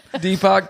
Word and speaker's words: Deepak: Spiritual teacher Deepak Chopra, is that how you say his name? – Deepak, yeah.